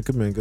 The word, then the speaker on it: Kuminga: Kuminga. – Yeah, Jonathan Kuminga.